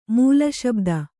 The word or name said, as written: ಮೂಲಶಬ್ದ